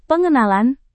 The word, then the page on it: pengenalan: Indonesian